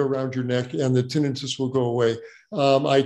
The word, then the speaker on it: tinnitus: And by the way, the word is pronounced tinnitus, not tinnitus.